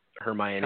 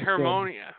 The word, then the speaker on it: Hermione: Hermione.